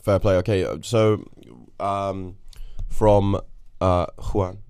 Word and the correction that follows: Hoi An: am I saying that right? Hoi An. – Hoi An.